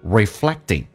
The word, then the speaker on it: reflecting: OK, 那么我们来看一下 And this will be reflecting. 美式发音当中那个 reflect, 那个 T 发成 D 这个发音叫 reflected, 而且可是不用发音的。在美式发音两个辅音在一起只用发后面一个。那么所以前面那个就不用发音叫 reflect 跟 in 还有一个连叫 reflecting, 投射到 your thoughts 跟 in 有一个连叫 thoughtsen, emotional state of mind 指的是你头脑的情绪的状态。